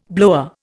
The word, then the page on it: blower: Indian